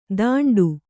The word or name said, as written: ತಾಂಟು